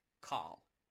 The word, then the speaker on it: qal: And when it says create there, that Hebrew word we translate as create is spelled Q-A-L, call. And it means to shape or fashion.